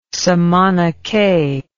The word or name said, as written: samanacay